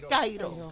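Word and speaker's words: Cairo (καιρός): Así, Cairo. – Como si fuera el Cairo Egipto, pero con ese al final.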